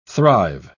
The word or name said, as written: thrive